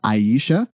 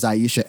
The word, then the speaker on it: Aicha: I think you pronounce her name Aisha Evans because it is spelled A-I-C-H-A. So I hope I did pronounce that correct. I think it is Aisha Evans has been the CEO for the past 2 years.